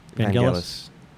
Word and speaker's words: Vangelis: Is it Vangelis? Vangelis? Vangelis? Whatever. – Vangelis.